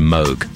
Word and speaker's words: Moog: You share your birthday with the inventor of the Moog synthesizer, although he pronounced his surname Moog, but everybody called it the Moog.